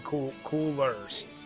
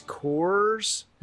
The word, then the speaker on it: Coors: Being from Philly, do you just say Coors, or do you not help yourself by saying Coolers? – Coolers. – Like no. Like the way we pronounce it, I know it's Coors. – Right. – But I'm saying sometimes, like when you hear from Philly, like people say like Coolers.